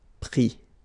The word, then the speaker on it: pris: Mettre, mi, and then E N D R E, I S, but then same thing here, the sound I. Prendre, pris.